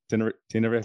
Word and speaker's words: Tenerife: Did I say that right? Tenerife? – Yeah, perfectly.